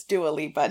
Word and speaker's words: Dua Lipa: It's Dua Lipa.